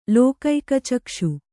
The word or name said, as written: ಲೋಕೈಕಚಕ್ಷು